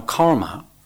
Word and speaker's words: karma: Now, karma,